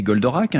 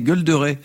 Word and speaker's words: Goldorak: C'est du dessin animé Goldorak. Hein. Charline, vous allez rire, vous allez voir. Mais pendant longtemps, j'ai cru qu'on disait Golderay. C'est amusant, non? – Alors, en trois syllabes, O-D-K.